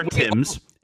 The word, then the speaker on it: Thames: Yeah, you just skip most of the letters. – Okay, oh, look, that is not fair. – That's the River Thames. It's spelled Thames.